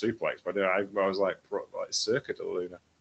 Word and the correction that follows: Suplex della Luna: Suplex della Luna, which he rarely hits because it's so difficult to get on, which is highly inspired by Joshi wrestlers.